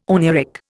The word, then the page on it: oneiric: Indian